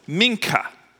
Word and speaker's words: Mincha: The ninth hour, and this hour of prayer is pronounced Mincha, which basically means gift or offering.